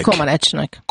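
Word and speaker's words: Comăneci: Egyáltalán, Zsuzsa, hogy ejtik a románok a nevét? Comanecinek vagy Comanecinek? – Comanecinek.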